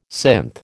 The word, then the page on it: synth: American English